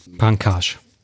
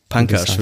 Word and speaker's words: Pankaj: Ähm, ich bin auf das Thema gekommen, weil ähm, ich einen Artikel gelesen habe von ähm, Pankra Mishra, oder wie würdest du ihn nochmal aussprechen? – Ähm, habe ich den Namen gerade hier stehen? Pankaj. – Pankaj, würdest du sagen, ja.